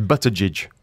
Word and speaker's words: Buttigieg: The G's are soft, jidge at the end. At the beginning, it's kind of like butter. So it's Buttigieg.